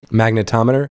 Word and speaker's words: magnetometer: like he refers to like magnometer, whatever you call it. Like, magnetometer? – Yeah, magnetometer, yeah, I missed a syllable in there, but you know, like metal detectors, right?